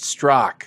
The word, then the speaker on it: Straka: It's spelled Straka, but it's pronounced Strock.